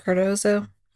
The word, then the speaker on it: Cardozo: So he was attending Cardozo High School. Is that how you say it? Cardozo? – I love that you're asking.